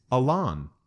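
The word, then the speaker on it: Alan: But according to some sources, Chester chose to pronounce his middle name with the emphasis on the second syllable. Alon.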